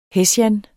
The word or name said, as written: hessian